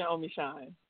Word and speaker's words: Omeshun: Yes, it's Christian Omeshun. – And where did that come from? – So the name Christian Omeshun, so funny story. Now, Omeshun is my little brother's middle name. His middle name is Omeshun.